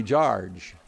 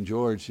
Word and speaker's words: George: And so, but my first name is George, and that's what I'd like you to remember me by, is George, or if you're from American FARC, it would be Jarge. But today we'll pronounce it in a more sophisticated way. My name, George, it kind of starts and ends the same. George. And it kind of fills your whole mouth up to say it.